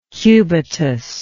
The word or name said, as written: cubitus